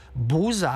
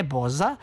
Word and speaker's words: boza: U mom kraju Semberija, to piše sve Dževad Hadžić, se kod starijih ljudi govorilo buza, a ne boza, i vjerovatno je to ispravan izgovor u turskom originalu, sigurno.